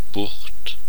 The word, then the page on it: Bucht: /bʊxt/